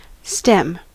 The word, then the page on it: stem: American English; [stɛm]